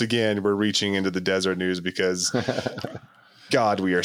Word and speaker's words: Deseret: Because like this podcast is how I learned that it's pronounced Deseret, because I always assumed it was Deseret.